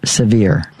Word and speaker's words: Sevier: Sever, you know? – Kathy said it's pronounced Sevier. Sevier County. – Sevier? – Mm-hmm.